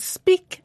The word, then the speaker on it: speak: And speak and break are pronounced differently, although they both have E-A-K at the end.